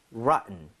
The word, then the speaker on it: rotten: rotten보다 사실은 rotten처럼 T 사운드가 굉장히 약화되거든요. 네. 한번 이거 발음해 보도록 하죠. 오케이. rotten.